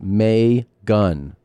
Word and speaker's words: Megan: Megan, pronounced May-gun.